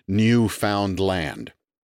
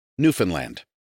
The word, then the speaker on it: Newfoundland: Newfoundland is not enunciated as if it were three distinct words, as in Newfoundland, nor is it pronounced as if it were somehow a Scandinavian colony, as in Newfoundland. Instead, it is Newfoundland. The key is to say it very fast.